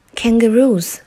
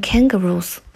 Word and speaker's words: kangaroos: Kangaroos RuPaul。 Kangaroo 就是袋鼠，要注意它的发音，重音在 ru 前面，不要念成 kangaroos，而是 kangaroos。